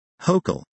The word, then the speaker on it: Hochul: Hochul, which rhymes with local, was among the first politicians to call out a recent spate of anti-Semitism in the state, in May 2019.